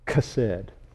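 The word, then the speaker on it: Chesed (חסד): The Strong said it's pronounced K, if you were to pronounce it, Chesed.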